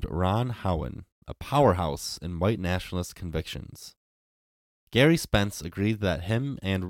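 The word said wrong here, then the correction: Gerry: Jerry. – Oh, my God. – It is spelled with a G, so I'll give you a little leeway.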